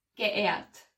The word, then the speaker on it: geehrt: You don't pronounce the H, so it's geehrt.